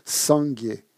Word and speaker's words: sangye: Sanguier. ça veut dire Bouddha. Hein. Et il est constitué de deux syllabes, sang et guier.